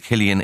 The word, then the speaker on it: Cillian: They're just saying Cillian instead of Killian. It's a C rather than a hard K, but they seem to be struggling with this.